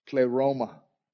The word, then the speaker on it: pleroma: The word translated fullness is the Greek word pleroma, P-L-E-R-O-M-A. It's a word you want to know, pleroma. It's how you say it. P-L-E-R-O-M-A is the Greek word.